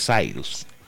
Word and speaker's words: Cyrus: Tengo la duda si se debe pronunciar Cyrus o Cyrus. Debe ser Cyrus.